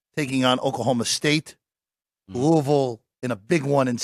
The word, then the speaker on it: Louisville: Louisville. – Louisville. – It's like Louisville. – One syllable.